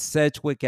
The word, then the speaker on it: Sedgwick: It said, how do you pronounce it? Because I call it Sedgwick. Is that how you pronounce it? – Yeah, it's Sedgwick Avenue.